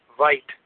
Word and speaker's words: Veith: Now, I was listening to another great Walter Veith teaching. And if you don't know who Walter Veith is, it's his last name is pronounced Veit, but it's spelled V-E-I-T-H.